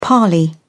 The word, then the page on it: parley: British English